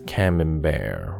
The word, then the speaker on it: Camembert: Since Camembert rhymes with beware,